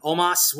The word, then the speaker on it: Omos: Uh, we got AJ Styles and Omos, which is how they're saying his name is pronounced now uh, going up against Elias with Jaxson Ryker in his corner.